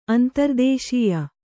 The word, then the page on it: ಅಂತರದೇಶೀಯ: antaradēśīya